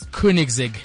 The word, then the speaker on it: Koenigsegg: Koenigsegg XR, which is worth £3 million.